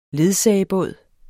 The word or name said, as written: ledsager